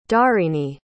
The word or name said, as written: dharini